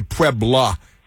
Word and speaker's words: Puebla: And the Spanish, it's the 5th of May, of course, celebrate Mexico's victory over the French Empire at the Battle of Pueblo, which it's actually Puebla, isn't it? I think you pronounced it correctly, and then I didn't. But Battle of Puebla, because it ends with an A, and it's not just your Pueblo like an old stucco house or something, but...